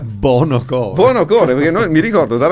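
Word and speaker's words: Buonocore: Abbiamo cominciato con un pezzo di Nino Buono Cuore, cioè Buono Core. Io da ragazzo non riuscivo mai a pronunciare bene il suo cognome, dicevo sempre infatti, Buono Core.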